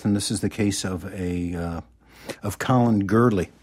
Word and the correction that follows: Gourley: if I'm pronouncing that Gourley. – Right. Gourley.